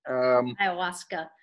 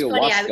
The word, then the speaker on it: ayahuasca: I don't remember when, but ayahuasca. So maybe just am I even pronouncing it right? – Ayahuasca. Yeah, it's ayahuasca. Funny.